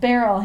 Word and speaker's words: Beryl: And so Margaret signs as Beryl. – He's like, oh, it rhymes with peril.